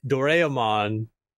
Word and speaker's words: Doraemon: doraemon uh, Doraemon.